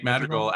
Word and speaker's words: Madrigal: Medrigal? Is that how you pronounce it? – Nick Madrigal out of Elk Grove, California, baby.